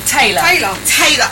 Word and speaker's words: Taylor: Taylor. – Oh, do you know what? I don't know if it's Tyler Taylor. You know, the American Taylor.